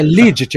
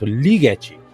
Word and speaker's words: Ligeti: é, é Ligeti. Eu, eu, eu, tive cuidado de ver como é que a pronúncia no, no, canal de YouTube lá do, de compositores é, Ligeti. O E é aberto, Ligeti.